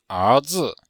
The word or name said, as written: er2zi5